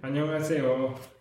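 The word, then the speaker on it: Annyeonghaseyo (안녕하세요): Hallo auf Koreanisch sind sieben Silben? Annyeonghaseyo. – Annyeonghaseyo. Fünf? – Ja.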